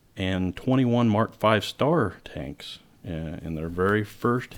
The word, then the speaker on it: Mark V Star: And that asterisk in early British tank designations was usually pronounced as a star when spoken.